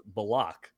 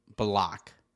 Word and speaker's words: Bullock: He's decided his last name is pronounced Bullock. – Bullock. – Instead of Bullock.